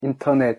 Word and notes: 인터넷: Korean

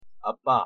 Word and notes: 아빠: Korean

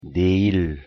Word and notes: 내일: Korean

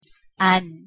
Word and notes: 안: Korean

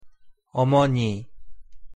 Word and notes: 어머니: Korean

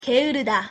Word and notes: 게으르다: Korean

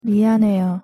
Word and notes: 미안해요: Korean

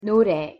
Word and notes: 노래: Korean

